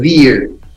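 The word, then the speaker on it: Veer: Pero Ver se escribe con B corta, WER, o sea, se pronuncia Cristóbal Tapia de Ver.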